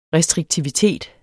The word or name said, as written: restriktivitet